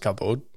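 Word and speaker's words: kavod (כבוד): And the direct Hebrew word for glory is actually kavod, or probably better pronounced kavod.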